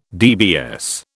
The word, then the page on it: DBS: British English